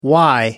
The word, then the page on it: why: American English